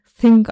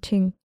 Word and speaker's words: Thing: Aber das wird ja auch nicht Thing ausgesprochen eigentlich, sondern Ting.